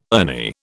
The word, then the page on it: Ernie: British English